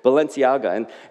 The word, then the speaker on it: Balenciaga: There was a photo that was flying around with Pope Francis wearing this white designer puffy jacket from Balenciaga. Is that correct? Can you just nod if I'm pronouncing that correctly? Thank you. A lot of nodding, a lot of affirmation there. Balenciaga.